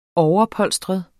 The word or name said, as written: overpolstret